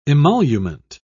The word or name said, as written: emolument